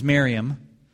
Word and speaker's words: Miriam: With few exceptions, Miriam, which is more accurate pronunciation of Mary.